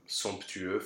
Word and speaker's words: somptueux: But you can have a word like somptueux, for instance, sumptuous, where it will be pronounced.